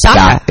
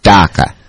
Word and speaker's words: Daqua: It's Daka. It's Daka. – Okay, and it's spelled D-A-Q-U-A.